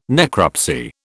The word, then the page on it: necropsy: British English